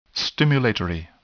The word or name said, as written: stimulatory